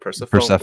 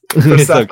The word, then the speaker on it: Persephone: I feel like it's Persephone, like the story of Persephone. – I'm so sorry, guys. Persephone.